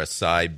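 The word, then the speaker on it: açaí: Now, every year there's something hot, it was pomegranate a few years ago, and then it was that acai or acai berry thing. – Acai. – Is that how you say it? Acai.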